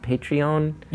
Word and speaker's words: Patreon: That's why something like, I'm so fascinated by, is it pronounced Patreon? – Yeah.